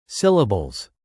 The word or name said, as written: syllables